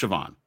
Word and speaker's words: Siobhan: Siobhan. – Kevin Adkins. What is it? – Siobhan. – Is it Siobhan? It's S-I-O-B-H-A-I-N. – Siobhan Yale worked with me at ESPN. – It is pronounced Siobhan.